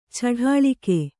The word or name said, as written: ಚಡಾಳಿಕೆ